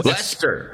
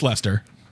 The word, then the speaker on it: Leicester: It's pronounced Leicester Square. – It's Leicester. – Yep, it's Leicester.